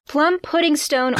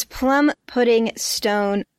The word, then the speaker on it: Plum Puddingstone: You said it wrong. – Plum Puddingstone Orchard, because this place, the name is Plum Puddingstone Orchard, aka there's like a bunch of words happening here. Unlike Puddingston, we say the stone here for this one.